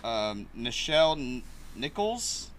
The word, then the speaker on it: Nichelle Nichols: Nichelle Nichols? – Yeah, that's how you say it.